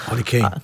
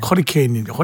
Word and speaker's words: 허리케인: 허리 케인. – 아니 따라 하시면 따라 제대로 하셔야죠. – 아, 허로 들어가야 되는데 커로 기억이 발음이 나오는 바람에 이게 커리 케인인데 허리 케인 때문에 커리 커리 드시고 싶으세요? – 맛있죠.